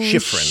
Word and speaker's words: Schifrin: I can't pronounce his name, but Lalo Schifrin. – Schifrin. Yes. Yeah.